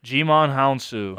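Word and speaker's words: Djimon Hounsou: Jimon Hounsou.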